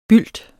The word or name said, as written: bylt